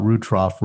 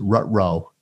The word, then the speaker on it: Rutrough: But for people who are looking at it on a map, it looks like it's like a lot of people want to pronounce it Rutrow. – Exactly. – R-U-T-R-O-U-G-H.